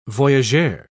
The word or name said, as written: voyageur